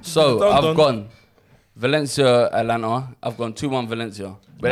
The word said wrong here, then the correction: Atalanta: that still puts Atalanta through. – Thank you for pronouncing them correctly eventually.